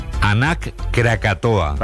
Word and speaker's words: Anak Krakatoa: Nos vamos a Indonesia, donde la erupción del volcán, vamos a ver si lo decimos bien, Anak Krakatoa.